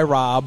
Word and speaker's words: Rob: I go by Rob, but it's spelled differently. It's spelled like Broccoli Rob, R-A-B-E, but I dropped that and go by R-O-B.